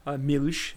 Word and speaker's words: Milch: And his mother went to the authorities and said, my Jewish father, my Jewish husband, Anton Milch, Milch is how they say it, is not the father of my six children.